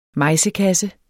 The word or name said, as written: mejsekasse